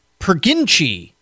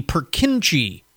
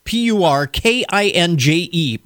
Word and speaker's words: Purkinje: The phenomenon of the Perginchi effect. Is that how you pronounce it? The Purkinje effect. P U R K I N J E, Purkinje effect.